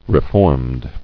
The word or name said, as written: Reformed